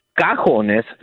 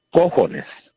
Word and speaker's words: cojones: Cajones, C-A-J-A, is drawers. But like I told your screener, for some reason, you know, other people than Mexicans or Hispanics always say cajones, which is not the correct pronunciation. It's cojones.